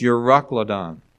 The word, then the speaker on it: Euroclydon: I had to go on Google and say, how do you pronounce that? And it's Euroclydon. I said, I had no idea how to pronounce that. Anyway, it's Euroclodon, and that's how you pronounce it.